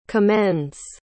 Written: commends

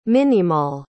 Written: minimill

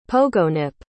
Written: pogonip

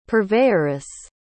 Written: purveyoress

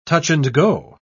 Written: touch-and-go

